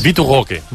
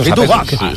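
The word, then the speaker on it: Vitor Roque: Vitu Goque. No, és així, ràpid. – Espectacular. – Vitu Goque.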